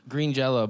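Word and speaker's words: Green Jellÿ: It's pronounced Green Jello, but they're not allowed to spell it Jello.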